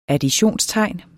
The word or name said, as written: additionstegn